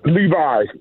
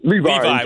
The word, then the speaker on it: Levey: Well, someone spelled your name L-E-V-E-Y. Is that how you spell it? – Well, that is my real name, but I said Levi because people end up calling me Levy.